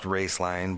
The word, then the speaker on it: Roeslein: pronounced Raceline but spelled R-O-E-S-L-E-I-N, in case anybody wants to look it up.